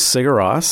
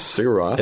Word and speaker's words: Sigur Rós: So this is Sigur Rós. – Sigur Rós. – Is how you pronounce the band name.